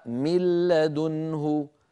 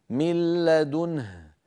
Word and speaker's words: من لدنه: أما مثال النون كما نرى فمثاله من قوله تعالى من لدنه، من لدنه، ملا، كان النطق قبل الإدغام من لا، نون ساكنة بعدها لام، بعد أن أدغمنا النون في اللام صار النطق بلام مشددة. هكذا انظروا كيف تصبح، هكذا أرأيتم كيف تقرأ، ترونها أمامكم مكبرة من لدنه ملا، ولا يبقى من النون شيء، لا ذاتها ولا غنتها، لا جزءها اللساني ولا جزءها الخيشومي، لأنها قد تحولت بجزئيها إلى لام، وأدغمنا اللام في اللام التي بعدها فصار النطق بلام مشددة كما ترونها أمامكم من لدنه.